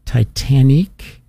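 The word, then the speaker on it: Titanique: The shows that were nominated for Best New Musical were the show Two Strangers Carry a Cake Across New York, Titanic is how you say it. Titanic. It's supposed to be Titanic, but with a Canadian flair. So Titanic, or with a French Canadian flair.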